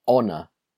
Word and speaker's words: honor: And it's also silent in the word honor. It was an honor to meet you. It was an honor to meet you. So sometimes, not often, a word beginning with H, the H is silent, and so we put an.